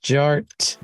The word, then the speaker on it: Jart: DJ Art, with two T's for a double dose of that. Tink, tink, the D is silent, so it's just Jart.